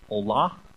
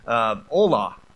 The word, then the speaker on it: olah: The term burnt offering in Hebrew is olah, and once again, pronunciation might or may not vary. Generally, I let John look at me and tell me, no, you're not pronouncing it right. But but anyways, olah. And what it means is,